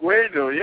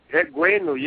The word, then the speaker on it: Egwuenu: Egwuenu. Yeah, it's the same. – Egwuenu. – You know, it's, yeah, Egwuenu, yeah.